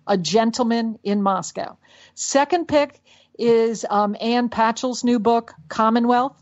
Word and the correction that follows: Patchett: Ann Patchett. Yeah. – Yep. Did I say her name right? Patchett. No, I forgot to cross my T's.